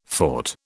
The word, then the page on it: fort: British English